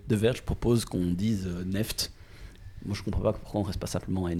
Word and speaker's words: NFT: On ne, dit plus, on ne dit plus NFT, mais neft. Enfin, c'est ce que le, le euh, magazine The Verge euh, propose. C'est ainsi qu'il faudrait prononcer ce token, qui est la mode.